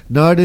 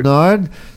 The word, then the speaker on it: Nared: Nard it is. – Yes. – And I knew I had it right, but I just wanted to double check. It's spelled N-A-R-E-D, but it's pronounced Nard.